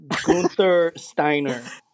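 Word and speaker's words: Guenther Steiner: Gunther Steiner.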